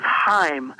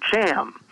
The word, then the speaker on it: Chaim: The, the actual pronunciation is Chaim, Chaim Weizmann, but uh, Harry Truman, President Truman, called him Cham.